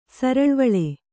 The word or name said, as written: ಸರಳ್ವಳೆ